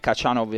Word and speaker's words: Khachanov: Cioè, Khachanov in cognome inizia con la X e invece il nome è Karen con la K. E quindi la pronuncia corretta in russo, come avete sentito, è differente.